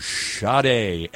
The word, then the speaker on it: Sade: And we started off this three-song set with Hang On to Your Love from Sade. S-A-D-E. Yes, and that is how you pronounce it.